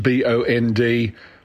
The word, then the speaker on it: Bondi: It's a big beach in Sydney, and you pronounce it Bondi, as in Bond-EYE, even though it is spelled Bondi, Bondi.